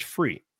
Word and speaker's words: Fure: But basically it's spelled FURE.CAB if you want to go to the website, but it's pronounced free.